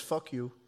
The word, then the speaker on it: Fugue: så det hedder The Art of Fugu. – Fugu. Det udtales fuck you.